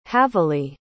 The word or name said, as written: havily